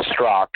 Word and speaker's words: Straka: Uh, if people go on my social media at Brandon Strock, and my last name is spelled like Straka, S T R A K A. Please don't worry if you mispronounce it. Everybody does.